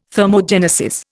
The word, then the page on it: thermogenesis: Indian